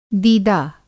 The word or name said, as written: दीदा